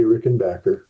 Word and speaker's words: Rickenbacker: For the record, by the way, it is pronounced Rickenbacker, not Rickenbacker. Please enjoy this Caro Pop conversation with John Hall. Just for the record, it's Rickenbacker, not Rickenbacker, right? – It is definitely Rickenbacker.